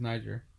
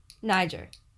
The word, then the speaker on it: Niger: The name of the country is Niger. – Niger. Yeah.